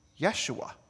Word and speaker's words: Yeshua: Interestingly, in Hebrew, his name is pronounced Yeshua.